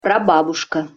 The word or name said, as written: prababuska